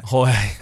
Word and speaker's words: Jorge: Maybe we ask And maybe we'll ask Jorge. Is Jorge right? Well, I'm saying that right. – Jorge. – Okay.